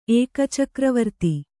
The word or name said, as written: ಏಕಚಕ್ರವರ್ತಿ